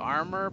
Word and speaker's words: Ahmyor: And it's not spelled like armor, but I think you pronounce it like armor. It's spelled Ahmyor.